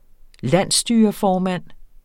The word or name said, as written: landsstyreformand